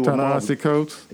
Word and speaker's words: Ta-Nehisi Coates: Uh, I'm gonna let you pronounce his name. You do Ta-Nehisi Coates. – Yeah, there you go.